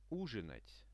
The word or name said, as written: uzhinat